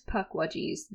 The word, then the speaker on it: Pukwedgie: And puckwudgie is spelled P-U-K-W-E-D-G-I-E. So it's spelled like puke wedgie, but it's actually puckwudgie.